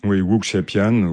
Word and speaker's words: wuxiapian: Donc, je vais peut-être mal le prononcer, le Wuxiapian. – Wuxiapian. Oui, donc ça veut dire film de sabre.